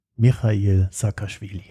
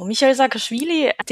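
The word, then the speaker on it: Michail Saakaschwili: Wer zur Hölle ist Michail Saakashvili? – Ja, die Aussprache war ganz korrekt, also alles super. Also Michail Saakashvili, den kennt man vor allem als ehemaligen georgischen Präsidenten 2003 bis 2013.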